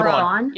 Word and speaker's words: Thrawn: Massive plot point that, oh no, I'm gonna get this name wrong, but I think it's Thawne. General Thrawn. – Yep. Thrawn. Thrawn. Admiral Thrawn.